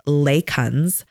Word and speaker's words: lichens: So I asked, do you have any experience with, okay, I'm going to spell it, L-I-C-H-E-N-S, and it's pronounced Lacons, derived vitamin D3 supplements.